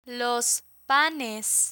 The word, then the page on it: los panes: Spanish